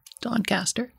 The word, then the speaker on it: Doncaster: Robin Hood Airport was actually Doncaster Sheffield Robin Hood Airport, and was, did I say that right? Doncaster? – Doncaster. – Doncaster. Yep.